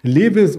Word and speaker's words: Levi's: Und da gibt es in dieser Woche beispielsweise noch Levis oder Tilray. Ähm, da geht es um äh, Cannabis und bei Levis natürlich oder Levi's, wie es ausgesprochen wird, geht es um ja, Bekleidungsmittel und eine Biofrontera.